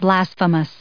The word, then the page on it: blasphemous: blas*phe*mous; American English